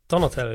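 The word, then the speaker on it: Donatell: Ed, wie wird er ausgesprochen? Donatel? Donatel, ja. Donatel?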